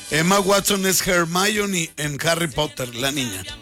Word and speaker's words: Hermione: Ya la ando rec- Hermione se escribe con H, Hermione, pero se pronuncia Hermione.